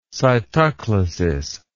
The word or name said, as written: cytoclasis